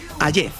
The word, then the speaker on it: Hayez: Vienes a descubrirnos el cuadro El beso de Hayez, se pronuncia, ¿no? – Hayez.